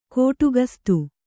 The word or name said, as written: ಕೋಟುಗಸ್ತು